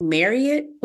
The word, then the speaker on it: Marriott: So everybody knows Marriott, which apparently is pronounced Marriott, which I don't agree with, but that's another conversation for another day.